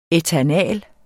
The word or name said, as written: ætanal